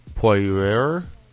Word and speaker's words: Poirier: And later on coming up, I don't know really how to pronounce this fucking name, but it's P-O-I-R-I-E-R. Poirier.